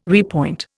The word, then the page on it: repoint: Indian